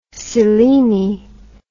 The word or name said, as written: seleni